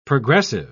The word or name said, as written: progressive